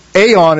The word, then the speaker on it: aeon: It's really talking about, it's the Greek word aeon is kind of how it's pronounced, but it means ages.